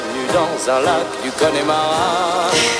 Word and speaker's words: Connemara: Die Seen des Connemara, denn Connemara wird eigentlich englisch ausgesprochen, ist eine mystisch aussehende Torfmoorlandschaft in Irland.